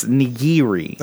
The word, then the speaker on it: nigiri: It's three. It's nigiri.